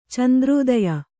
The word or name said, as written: ಚಂದ್ರೋದಯ